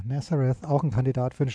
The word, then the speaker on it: Nazareth: Übrigens, ähm, vielleicht ist es euch nicht aufgefallen bei dieser Version, aber es gibt eine sehr bekannte Coverversion der Band Nazareth oder wie sie auf Englisch ausgesprochen werden. Nazareth. Nazareth. Ja.